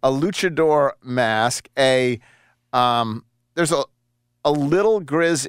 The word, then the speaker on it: luchador: Never owned them never had them so there you go is it how do you spell how do you pronounce luchador Luchador.